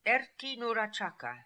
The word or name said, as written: Erti Noora Chaka